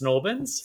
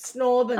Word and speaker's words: St. Albans: And everyone used to say it, if you, you would probably know this, St. Albans gets pronounced Snorbans. – Snorbans.